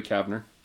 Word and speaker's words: Kavner: Kevner? – Yeah. – I'm getting the name wrong. – Julie Kavner.